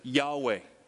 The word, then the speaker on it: Yahweh: And so if you transliterate that over to English, it would be YHWH. We sometimes pronounce that as Yahweh.